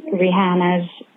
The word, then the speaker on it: Rihanna: I've been following your journey of pronouncing Rihanna's name right, and I just had a little bit of fun trivia for you. So it is pronounced Rihanna. By the way, it's an Arabic name, actually.